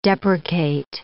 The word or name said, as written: deprecate